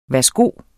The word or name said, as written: værsgo